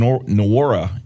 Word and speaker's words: Nwora: One thing that I did notice today, their top scorer, Jordan Nwora, N-W-O-R-A. Am I pronouncing that right, Sleepy? – I believe so, yes.